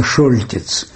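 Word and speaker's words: Choltitz: Тогда была большая кампания против приезда в Париж бывшего коменданта Парижа фон Хольтица, который по-французски читается Шольтиц, фон Хольтица, тот самый, который отказался поджечь Париж, что мы все знаем по великому фильму Рене Клемана «Горит ли Париж?».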